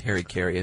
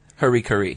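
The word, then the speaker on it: hara-kiri: Disgrace, if you surrender, and that's where the, how did you pronounce it? I always said Harry Carey. Of course, Bushido. Harry Carey is gut cut. – No. – Oh, Harikari? – Harikari.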